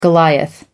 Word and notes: goliath: American English

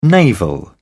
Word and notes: naval: British English

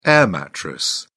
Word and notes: air mattress: British English

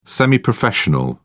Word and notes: semi-professional: British English

